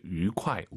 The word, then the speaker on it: 愉快: 유쾌하다 할 때 愉快, 위 아니고 입술 동그랗게 말아서 愉快 잊지 마세요.